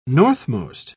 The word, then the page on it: northmost: British English